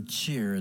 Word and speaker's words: Tchir: That's a song from Trevor Cheer. It's spelled T-C-H-I-R.